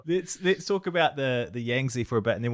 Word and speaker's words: Yangtze: Yangtze.